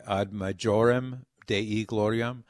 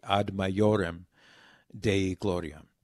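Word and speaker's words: ad maiorem Dei gloriam: So it's also used for I, but it's used for J in the Latin alphabet, which does not have a J. So when it's transliterated into English, many Americans would say ad majorem Dei gloriam, but that's just an anglicization of the Latin.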